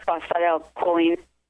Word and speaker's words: phosphatidylcholine: I had a question about the, and I forgive me, I always pronounce it incorrectly, the phosphatidylcholine? – Phosphatidylcholine, yes.